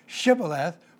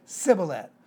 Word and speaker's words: shibboleth: And so rather than saying sheen, they would say seen, and therefore instead of saying shibboleth for ear of corn, they would say sibboleth.